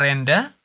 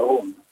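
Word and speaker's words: Rhône: Ja, det står Rone, er det Rende? R-H-O-N-E. – Ja, det er Rone.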